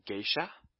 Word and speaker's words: geisha: E and I together forms a new, not a new sound. It forms, you'll see it in the word geisha. So you pronounce it as ge-i-sha. Geisha. Not a new sound like we do in English.